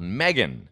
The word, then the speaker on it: M3GAN: There's a movie out in the theater today called Megan, but it's spelled M3GAN.